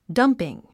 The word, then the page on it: dumping: dʌ́mpiŋ; ダンピン